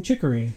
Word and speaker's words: Chicory: Chicory, am I saying that right? – That's right.